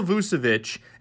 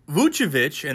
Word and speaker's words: Vučević: Vučević, and that is how you pronounce his last name, Vučević, not Vučević, it is Vučević, is a bona fide All-Star.